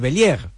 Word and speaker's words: Bellieres: Se escribe Bellieres, pero es Bellier, ¿no? – Sería la pronunciación. Sí, se pronuncia Bellier, sí.